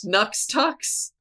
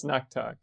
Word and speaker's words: Knuxtux: Oh, K-N-U-X-T-U-X. – Nux Tux? – It's pronounced Nuck Tuck. – Okay, the X is a fricative.